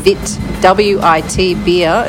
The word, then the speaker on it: Witbier: It's called Witbier. I'm going to pronounce it with a bit of a German accent. Wit, W-I-T, beer.